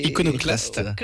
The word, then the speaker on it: iconoclaste: presque iconoclable. – C'est iconoclaste.